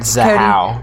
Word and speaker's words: Zahau: How do you say it, Zahow. – Zahow.